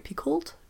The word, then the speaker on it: Picoult: I'm not even sure if I'm pronouncing her name right. Picoult? Picoult? – Oh, yep.